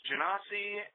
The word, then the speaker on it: Genasi: So it's Genasi, and it's Eladrin, and it's Ominator, and I can't think of any other controversial pronunciations.